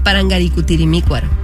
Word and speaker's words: Parangaricutirimícuaro: Parangacutirimícuaro. – No, mira, no, lo dijo. Dice Parangacutirimícuaro y es Parangaricutirimícuaro. – Le falta, falta una sílaba.